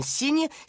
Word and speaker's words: Cygne: Le secret de beauté de Madame Cygne, c'est le son N. On entend N dans Cygne et dans les mots comme chignon, baignoire, et il s'écrit avec les lettres GN.